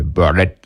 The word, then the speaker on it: Burdett: Et son frère, en l'artant Burdett, comme ils disent dans le film en VO, c'est Burdett. J'adore comment il prononce.